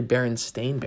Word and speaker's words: Berenstain: But it's actually Berenstain Bears, Stain, instead of Stein.